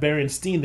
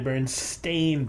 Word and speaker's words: Berenstain: So apparently, the Berenstain Bears they are not Berenstein; they're Berenstain Bears. We always pronounce them Berenstein, but it's an A in there instead of an E.